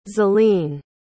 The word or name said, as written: zelene